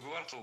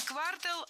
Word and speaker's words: квартал: Не квартал, а квартал. Причем такое ударение актуально при любом значении слова. И часть улицы, и часть года. Всегда в слове «квартал» ударяем второй слог.